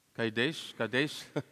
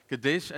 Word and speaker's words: Kadesh: Am I pronouncing those towns correct? Kadesh, Vaughan says like this. Kadesh and Bered.